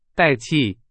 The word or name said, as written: 代替